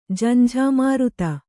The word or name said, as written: ಝಂಝಾಮಾರುತ